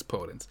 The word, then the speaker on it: Podence: But Podence, or Podence, as I've heard him pronounce, but it's Podence.